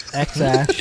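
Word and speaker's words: X Æ: And then the AE is like pronounced Ash. – What? – X-Ash. Yeah.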